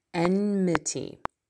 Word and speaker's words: enmity: These are the occasioned by love of the world, which means enmity with God. I can never pronounce that word. Enmity. E-N-M-I-T-Y. I always want to say E-M-N-I-T-Y. Enmity. But that's not the right way.